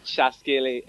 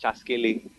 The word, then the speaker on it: Chaskele: And please correct me if I'm wrong, but it's titled Chaskele. What does that mean? – Chaskele. You said it right. It's Chaskele.